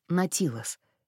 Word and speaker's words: Наутилус: «Если вам интересно, это произносится как «Натилос».